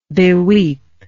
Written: beweep

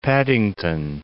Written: Paddington

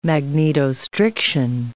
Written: magnetostriction

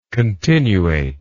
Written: continuateness

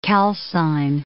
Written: calcine

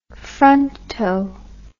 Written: fronto

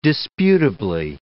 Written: disputably

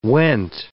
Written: went

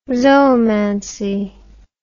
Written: zoomancy

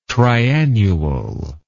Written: triannual

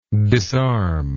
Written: disarmer

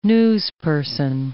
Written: newsperson

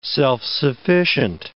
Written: self-sufficient

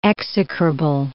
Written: execrable

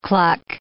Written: cluck

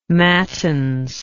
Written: mattins

